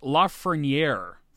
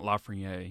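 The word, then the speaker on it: Lafrenière: Alexis Lafreniere, for those of you wondering. It's not Lafreniere. You got to pronounce the R. – Lafreniere.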